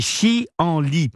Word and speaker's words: chienlit: Alors il faut séparer ces trois syllabes pour bien comprendre. Hein. C'est chienlit.